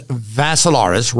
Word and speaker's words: Vassilaris: But if you want to see it, go to my Facebook page, Dimitri spelled with three I's, D-I-M-I-T-R-I. Last name is Vassilaris, rhymes with Asilaris, except you add a V to it.